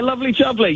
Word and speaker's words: Lovely Jubbly: Or what am I saying there? – Yeah, Lovely Jubbly.